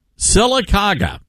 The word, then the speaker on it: Sylacauga: Sylacauga. – Sylacauga, okay.